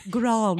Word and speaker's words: Grande: Pronounce the E. – Grande.